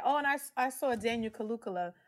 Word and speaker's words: Kaluuya: It's called Kaluuya. – Kaluuya. – Kaluuya. – Like Kaluuya. Hallelujah. Okay, Kaluuya.